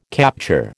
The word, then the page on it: capture: American English